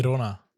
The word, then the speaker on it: Girona: Guck mal, ihr habt das auch letzte Folge gemacht und ich weiß, ihr frontet mich immer gerne wegen irgendwelchen Sachen, aber Girona ist absolut falsch ausgesprochen. Schon seit Ewigkeit. – Girona.